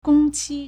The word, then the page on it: 工期: gōngqī